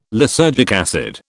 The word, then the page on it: lysergic acid: British English